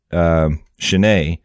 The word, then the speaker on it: Chenier: It's spelled C-H-E-N-I-E-R. I actually looked up the pronunciation because I wanted to say it right. I didn't want to embarrass myself. – That's not how I would have pronounced it. So yeah, I'm glad you looked it up.